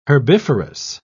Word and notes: herbiferous: British English

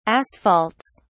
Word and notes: asphalts: British English

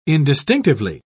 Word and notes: indistinctively: British English

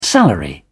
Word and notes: salary: British English

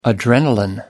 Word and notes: adrenalin: American English

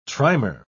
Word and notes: trimer: British English

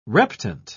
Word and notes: reptant: British English

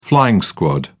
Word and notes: flying squad: British English

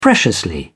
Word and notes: preciously: British English

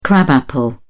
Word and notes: crab apple: British English

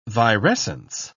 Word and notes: virescence: British English

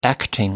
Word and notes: acting: American English

